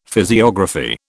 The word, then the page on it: physiography: British English